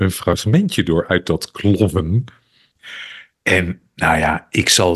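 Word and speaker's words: Klovn: En hij is gebaseerd op een Deense serie die heette Clown. Of Clown, maar dat spreek je uit als clown.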